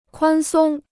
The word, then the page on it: 宽松: kuān sōng